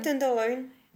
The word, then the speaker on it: Tenderloin: À Boundstone notamment, Tubby Tenderloin, je ne sais pas du tout comment on prononce. – Euh, ouais, Tenderloin.